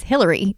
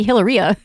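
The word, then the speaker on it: Hilaria: Well, her name is Hillary, but she wants to be Hillaria.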